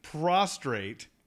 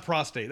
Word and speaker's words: prostrate: And so Webstor is lying just prostrate. – That's awesome. – Did I say that right? Yes. – Yes. – Not prostate. That's a whole different thing.